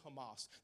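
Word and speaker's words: Hamas (חמס): It's spelled C-H-A, but when you say it in Hebrew, it says Hamas.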